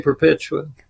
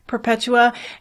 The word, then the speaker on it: Perpetua: And it is on Nicholas von Flue and Saint Perpetua. I don't know how to pronounce that correctly. How would you say it? – I would say Perpetua. – Perpetua.